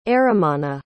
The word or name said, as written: eramana